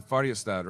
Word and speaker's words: Färjestad: So you're going to have to tell me if I'm saying this right. You played in Färjestad, right? – You got it right.